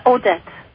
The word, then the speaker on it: Odette: It's Odette. It's spelled O-D-E-T-T-E.